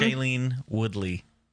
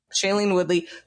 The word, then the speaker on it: Shailene Woodley: Shailene Woodley. – Shailene Woodley, who I have always hated.